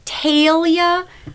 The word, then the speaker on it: Talia: And then people that weren't able to pronounce my name growing up in Minnesota and like a teacher would be like, Talia.